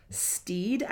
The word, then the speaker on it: Stede: So Stephen Bonnet was based, at least on name, as Steed, I think is how you say it. S-T-E-D-E.